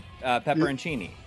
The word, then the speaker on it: pepperoncini: To be honest, I feel like my pronunciation of this is wrong, but I've always called them pepperonichis. – Oh, okay. Pepperoncini.